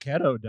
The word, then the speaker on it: keto: he's on that keto diet too. – Yeah, he's taking that turkesterone. – I think you better change that pronunciation because that didn't sound like keto. – Keto, like pedo.